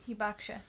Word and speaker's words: hibakusha: And they get called, you said it's pronounced hibakusha, right? – Hibakusha, yeah. – Yeah, it looks like it's spelled hibakusha, but hibakusha, I guess.